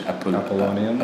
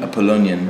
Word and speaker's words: Apollonian: It's almost a cliche, but it's like playing with the cliche of a high culture, of an Apollinius. I don't know how you say it really in English. – Apollonian. – Apollonian.